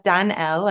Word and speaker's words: Danelle: This week, Danelle welcomes us on the show, and that's Danelle. I was asking how to pronounce it.